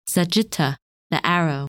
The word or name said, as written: Sagitta